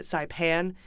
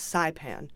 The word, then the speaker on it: Saipan: And I had pronounced it Saipan, uh, and someone wanted to let me know it's Saipan.